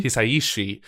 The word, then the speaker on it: Hisaishi: Hisaishi.